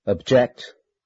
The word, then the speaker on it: object: E.g., conduct, contest, decrease, increase, export, import, object, record, transport. Are used both as nouns and verbs. As nouns, the stress is on the first syllable. As verbs, the stress is on the second syllable.